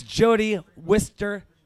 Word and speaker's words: Jody Wisternoff: It's Jody Wisternoff. – Hello. – I said it right. – Perfect, you nailed it.